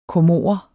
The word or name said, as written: comorer